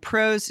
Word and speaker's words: Prose: And Pros is spelled P-R-O-S-E.